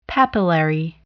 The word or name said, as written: papillary